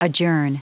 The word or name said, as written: adjourn